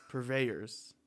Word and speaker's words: purveyors: Lemon Heart and Sun Rum Pervors. Purveyors. – Purveyors.